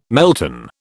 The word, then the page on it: melton: British English